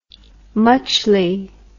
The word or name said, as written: muchly